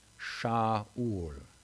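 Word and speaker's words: Sha'ul (שָׁאוּל): But Saul's name actually in Hebrew is two words. It's pronounced Sha'ul. And Sha'ul means asked for.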